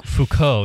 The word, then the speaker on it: Foucault: Oh, is that how you pronounce it? Foucault? – Foucault, yes. It's French.